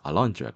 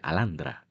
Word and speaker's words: Alundra: En este caso leímos la, una entrevista que le hicieron a los desarrolladores responsables de Alundra, Alundra, como se pronuncia, Alandra en japonés, Arandra, eh, la gente de Matrix Software.